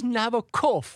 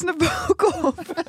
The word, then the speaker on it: Nabokov: Is, het is Nabokov. Dit is Nabokov.